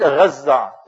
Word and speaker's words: Gaza: Gaza, the West pronounce it. We pronounce it Gaza, tajdeed on the za.